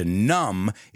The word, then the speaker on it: numb: And to numb is a word with a silent B.